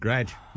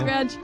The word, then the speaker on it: garage: Garage. – No. Garage. One syllable.